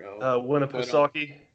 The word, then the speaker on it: Winnipesaukee: Winnipesaukee.